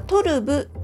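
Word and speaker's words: トルヴ: で、これ今回ご紹介するにあたって調べましたら、サブヒロモリさん、このね、私が愛用してるシリーズはトルブというシリーズでして、トルブのブはウニ点点ですね。ウニ濁点のトルブ、 V の発音ですけれども、このトルブのシリーズ、が私が使ってるやつでね、これどれもとってもこう色展開とかもね素敵なんですよ。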